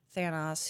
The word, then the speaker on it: Thanos: Basically, Thanos, however you want to pronounce it, is Thanos. – They say it Thanos in the movie.